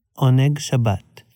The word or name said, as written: Oneg Shabbat